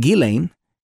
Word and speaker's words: Ghislaine: When I read the news that Ghislaine, spelled Ghislaine Maxwell, I understand it's pronounced Ghislaine, was arrested at a $1 million New Hampshire estate.